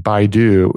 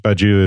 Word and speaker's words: Badiou: it must be Badiou because, and I was pronouncing it wrong last time because I was saying Baidu and I looked at his name and it's definitely not Baidu because it's B-A-D-I-O-U. So it's Badiou.